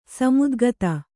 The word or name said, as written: ಸಮಧಿಗತ